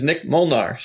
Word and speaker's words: Nik Molnar: My name is Nik Molnar, said the exact same way.